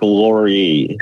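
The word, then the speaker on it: Lore: When you introduced us to Mark Lohr, I sort of had one idea that I wasn't going to like this guy, and I kind of like this guy. – Listen, it's pronounced Mark Laurie.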